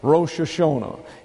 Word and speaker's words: Rosh Hashanah: Rosh Hashanah is how that is pronounced, and that's New Year's Day.